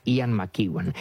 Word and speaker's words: Ian McEwan: Ian, Mac, es M-C pequeñita, y Evan, creo que se pronuncia Ian McEwan.